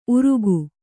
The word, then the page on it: ಉರುಗು: Kannada